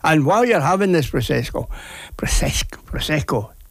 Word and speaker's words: Prosecco: Today is Prosecco Day. It is. I hope I pronounced that right. Prosecco Day. Prosecco, that's what it is, yeah.